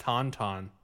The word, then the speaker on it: Tauntaun: Atomic Tantrum Overdrive. – I think it's actually pronounced Tauntaun. – What? Oh my gosh, you're right. It is Tauntaun.